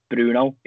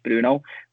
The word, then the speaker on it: Bruno: So another example, we would pronounce Bruno in English, Bruno, whereas in Portuguese it's very soft O at the end, so it's more like Brun. So it's more like a Brun Fernandes, whereas in English it's Bruno Fernandes.